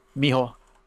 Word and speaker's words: mijo: It sounded like, if anything, it actually sounded like Miho, which the whitest way ever would be Jesus Mijo. – Oh, that's true. Because they spell it starts with a J in Spanish.